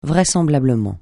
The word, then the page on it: vraisemblablement: francais